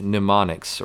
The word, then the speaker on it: mnemonics: So we all know the word mnemonics, right? – Mnemonics. – Mnemonics. It starts with an M. – It rhymes with bubonics. – The M is silent, kind of like in pneumonia, the P is silent for some reason.